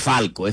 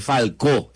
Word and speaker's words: Falcó: No es Falco, es Falcó, el acento en la O.